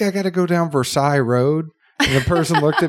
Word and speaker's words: Versailles: And I was like, well, to be very nice, it's actually Versailles. Everything is like two syllables here.